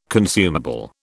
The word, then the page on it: consumable: British English